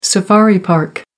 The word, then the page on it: safari park: American English